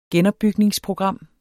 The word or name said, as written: genopbygningsprogram